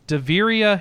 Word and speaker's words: Davyria: Deveria Heroes of Eternal, Here is Daviria Heroes of Eternity. That is spelled D-A-V-Y-R-I-A.